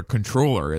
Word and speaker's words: comptroller: And I think you actually may be supposed to pronounce comptroller controller as well in cities that have it.